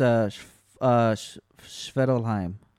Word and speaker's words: Svartalfheim: I'm looking up the word because I know what it, I know how to say it. Yeah, it's Svartalfheim. – Svartalfheim. Okay, so the F is silent.